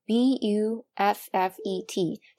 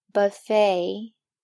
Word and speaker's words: buffet: 那自助餐 b u f f e t，它的发音可能你已经猜出来了，buffet，buffet。